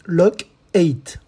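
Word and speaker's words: Loc-8: À l'écoute, c'est Locate en quatre lettres. Et oui, Locate, cela s'écrit L-O-C, il y a un trait d'union, et le, chiffre 8, Lock 8. Voilà en anglais.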